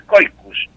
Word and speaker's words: caucus: Pero el caucus, que se pronuncia coicus, ¿no? La pronunciación es coicus,